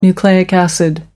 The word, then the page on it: nucleic acid: American English